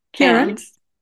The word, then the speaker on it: Cairns: And when I went on these retreats, this recently, in fact, I was in Cairns. I don't say it correctly. I know I say it American. Cairns. – Oh, Cairns.